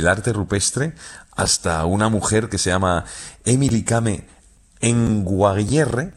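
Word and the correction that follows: Emily Kame Kngwarreye: lo he dicho mal. – Emily Kame. – Con Guarreye. – Emily Kame con Guarreye, sí.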